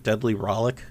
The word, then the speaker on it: Deadly Rollick: Deadly Rollick, which is starting to see some more play. Did I say that right? Deadly Rollick? – Yep.